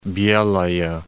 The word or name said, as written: Belaya